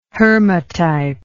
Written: hermatype

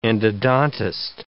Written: endodontist

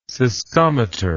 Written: cystometer

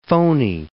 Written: phony

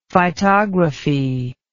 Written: phytography

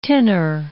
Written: tinner

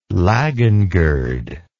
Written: laggen gird

